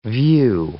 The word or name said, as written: view